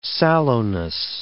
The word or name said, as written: sallowness